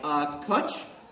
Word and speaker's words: Koch: Kutch. Oh, thank God. It was not a long O sound.